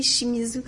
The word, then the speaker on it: Shimizu: C'est la pièce euh, Eight Desires par le compositeur saxophoniste japonais Yazuki euh, Shimuyuki. Euh, est-ce que vous pouvez nous en... Oui, j'ai peut-être mal prononcé son nom. Est-ce que vous pouvez nous en dire un mot? Yasuaki Shimizu. Oui, ben en fait, c'est un, un jazzman japonais très très connu.